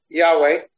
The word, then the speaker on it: Yahweh: So he says, the Greek transcriptions reflect pronunciation closer to, close your ears if you don't want to hear what Alter's written, Yahweh.